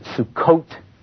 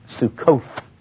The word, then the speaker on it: Sukkot: And it is Sukkot, not Sukkoth in Hebrew.